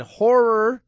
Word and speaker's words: horror: Horror, got to say the extra syllable, horror show host, horror film fans.